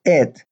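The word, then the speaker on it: et (את): Grammatically, in Hebrew, the Aleph and the Tav, pronounced et, are used to point to the direct object of the sentence.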